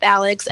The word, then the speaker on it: Alix: My blog's Instagram is just Live with Alix, LiveWithAlix.